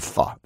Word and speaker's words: Pho: I Love Pho. That's how you pronounce it.